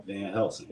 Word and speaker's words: Van Helsing: In these last days, face Satan, Van Helsing. Can I say that right? – Van Helsing. – Yeah.